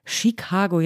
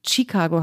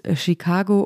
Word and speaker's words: Chicago: Chicago, jetzt sage ich es direkt richtig, habe ich falsch ausgesprochen, nämlich mit einem viel zu starken T, also Chicago habe ich gesagt. Also ich habe es sehr deutsch ausgesprochen in diesem deutschen Podcast, aber natürlich jeder, der die Stadt so liebt, muss sie auch richtig aussprechen. Es ist Chicago